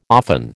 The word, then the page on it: often: American English